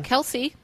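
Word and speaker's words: Kelsea: And it's got someone on the cover named Kelsey or Kelsia, however you say her name. – Kelsey.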